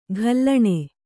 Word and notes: ಘಲ್ಲಣೆ: ghallaṇe

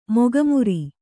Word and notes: ಮೊಗಮರೆ: kannada

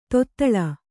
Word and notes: ತೊದಳ್: kannada

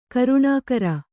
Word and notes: ಕರುಣಾಕರ: karuṇākara; kannada

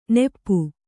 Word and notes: ನೆಪ್ಪು: neppu

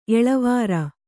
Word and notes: ಎಳವೆರೆ: eḷavere

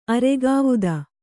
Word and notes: ಆರಗ್ವಧ: kannada

